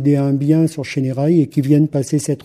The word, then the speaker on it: Chénérailles: Oui, effectivement, on doit prononcer les « et », donc ce n'est pas « Chénérailles », mais c'est bien « Chénérailles ».